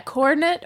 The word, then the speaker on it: Cornette: Yeah, Cornette or Coronet? – It's spelled C-O-R-N-E-T-T.